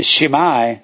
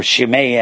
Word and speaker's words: Shimei: And uh, he's a Benjamite, and he's going over here chasing David, and Benjamin, the Shimei, or Shimei, however you want to pronounce it. Shimei, I got it spelled wrong.